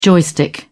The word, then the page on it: joystick: British English